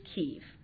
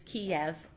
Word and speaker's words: Kyiv: So the pronunciation of the capital in Ukrainian is Kyiv, and it is Kiev in Russian.